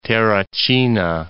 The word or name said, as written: Terracina